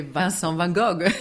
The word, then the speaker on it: Vincent van Gogh: And in French, I say Vincent van Gogh. It depends. I think every language has their own pronunciation, and that's perfectly fine. He's such an international artist by now that I think we can all use our way of pronouncing it.